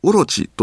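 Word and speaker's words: 落地: 船坂峠を西に下った梨ヶ原には、落ちる地面の地と書いて、おろちと読む珍しい地名があります。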